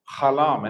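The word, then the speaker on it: Halam (סלם): Halam, and it's spelled Samak Lamed, and then it's got the closed mem, the mem sofit on the end.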